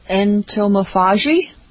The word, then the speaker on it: entomophagy: E-N-T-O-M-O. How did you pronounce it? – Entomophagy. – Yeah, I think we're putting the accent on the wrong syllable. – Entomophagy? – I think it's ento.